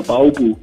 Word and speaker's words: Ballwil: Baubu nennen wir dazu, Ballwil. Guten Morgen. – Guten Morgen. – Hilf mir schnell, Baubu, habe ich es richtig ausgesprochen? – Richtig, ja, Ballwil, also Baubu.